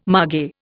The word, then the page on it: mage: Indian